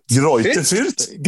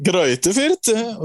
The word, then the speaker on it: Greuther Fürth: Greuther Fürth. – Greuther Fürth.